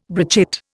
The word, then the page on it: brochette: Indian